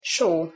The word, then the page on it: so: Hungarian